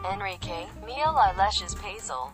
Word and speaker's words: Enrique Miguel Iglesias Preisler: Enrique Miguel Iglesias Preisler, Spanish pronunciation, Enrique Miguel Iglesias Preisler,